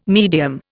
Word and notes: medium: Indian

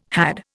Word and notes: had: Indian

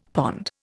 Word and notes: pond: Indian

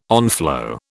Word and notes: onflow: British English

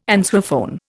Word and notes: answerphone: Indian

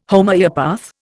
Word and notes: Homoeopath: Indian

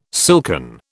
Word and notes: silken: British English

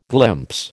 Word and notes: glimpse: American English

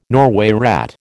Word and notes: Norway rat: American English

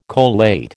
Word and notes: collate: American English

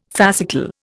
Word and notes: fascicle: Indian

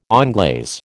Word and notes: onglaze: American English

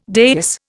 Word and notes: dais: Indian

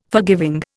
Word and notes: forgiving: Indian

